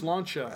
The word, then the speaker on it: Sláinte: So I bet no one knows that I know how to pronounce that. That's Sláinte.